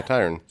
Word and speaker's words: Tyron: So Tyron Smith, kind of if like Byron Leftwich, that's how you say it.